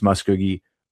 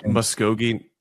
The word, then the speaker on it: Muskogee: It's AI data centers in Austin, its Denton facility, Dalton, its Marble facility, and its Muskogee, Oklahoma facility. Most of those are in Texas, except for the Muskogee one. – It's pronounced Muskogee.